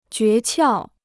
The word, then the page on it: 诀窍: jué qiào